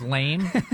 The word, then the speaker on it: Laime: L-A-I-M-E, lame. – His last name is lame. Is actually lame, L-A-I-M-E. It's spelled, yeah. – It's pronounced lame.